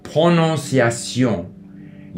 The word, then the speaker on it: prononciation: I also remember trying very hard to improve my French pronunciation, because even though we say like prononciation,